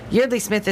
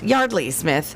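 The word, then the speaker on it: Yeardley Smith: Yardley Smith is 61. Oops, excuse me, her name is pronounced Yardley Smith.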